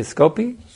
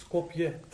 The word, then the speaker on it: Skopje: How do you say Skopje? – Skopje. – Skopje. – Yes. – We appreciate so much your nice, how you're serving Prabhupada's mission very nicely.